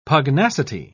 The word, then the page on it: pugnacity: British English